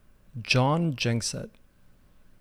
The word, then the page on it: Jon Gjengset: English pronunciation